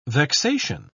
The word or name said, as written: vexation